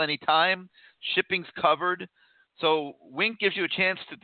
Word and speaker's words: Winc: I posted about them on the message board, and that's Wink. It's spelled W-I-N-C, and Wink makes it easy to discover great wine.